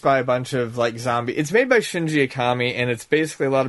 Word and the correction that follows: Mikami: Jesus, I'm saying his name wrong now. Shinji Mikami?